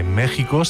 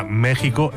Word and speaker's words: México: Bueno, lo que está claro es que México se pronuncia México en castellano y no México, que a veces se oye.